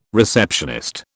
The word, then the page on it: receptionist: British English